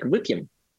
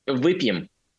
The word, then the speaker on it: выпьем: In Russia, we just say like выпьем. – How do you say it? – Выпьем. – Выпьем. – Yeah, it literally means let's drink or have a drink.